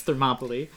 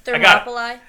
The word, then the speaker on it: Thermopylae: On the shores of Thermopylae? What's the place? – I don't know. Are you saying the place they're at? Because, yes, it's Thermopylae. – Okay. – Thermopylae. – Thermopylae. Damn it! So close.